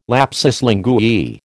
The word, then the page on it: lapsus linguae: American English